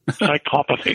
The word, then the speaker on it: psychopathy: Psychopathy. – Psychopathy.